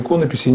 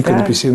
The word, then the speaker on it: иконописи: Я специально говорю иконописи, а не иконописи. Намеренно ставлю ударение так, считаю, что это правильно.